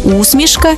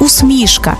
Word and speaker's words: усмішка: До речі, тут подвійний наголос – усмішка і усмішка, як у Василя Симоненка.